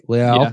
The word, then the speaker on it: Leal: And I love Jordan Davis, but if Leal, right? Leal, pronounce it like he's a Brazilian.